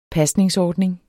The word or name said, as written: pasningsordning